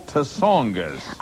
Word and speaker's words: Tsongas: The only Democrat brave enough to throw his hat in, as of then, was Senator Paul Tsongas. – I think the T is silent.